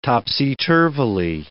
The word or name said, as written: topsy-turvily